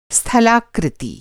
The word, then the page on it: स्थलाकृति: Hindi